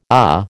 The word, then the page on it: AH: American English